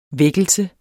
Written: vækkelse

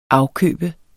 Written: afkøbe

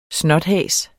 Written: SNOTHAS

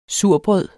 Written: surbrød

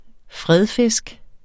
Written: fredfisk

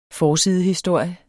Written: forsidehistorie

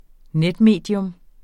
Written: netmedie

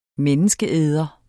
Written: menneskeæder

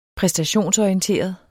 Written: præstationsorienteret

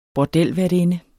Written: bordelværtinde

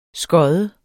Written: skodde

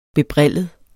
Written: bebrillet

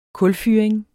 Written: kulfyring

